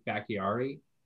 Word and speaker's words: Bakhtiari: Actually, their left tackle, Bakhtiari, is that how you say it? – Yeah.